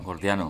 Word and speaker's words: Gordiano: lleva al nudo Jordiano, ¿lo he dicho bien? Jordiano. – Gordiano, sí. – Gordiano, eso.